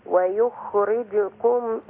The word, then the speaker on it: ويخرجكم: أنا أخلي ضمة الجيم زي الكاف، ويخرجكم إخراجا، والله جعل لكم الأرض بساطا، لتسلكوا منها سبلا فجاجا.